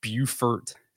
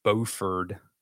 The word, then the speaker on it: Beaufort: So a little quick tip, if you're not from North Carolina or from South Carolina, so you have Beaufort, South Carolina, and then you have Beaufort, North Carolina. Same spelling, same word, completely different pronunciation.